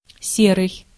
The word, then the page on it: gray: /ɡɹeɪ/